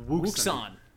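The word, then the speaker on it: Wouxun: So any which way, we pronounce it Wuxon. – Yeah. – W-O-U-X-O-N.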